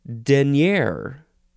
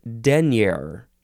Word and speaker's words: denier: But number two is pronounced denier, or maybe it's denier. The emphasis is on the first syllable.